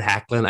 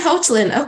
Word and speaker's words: Hoechlin: I picked that up from his Twitter account. Tyler Hoechlin, I believe, is how it's pronounced.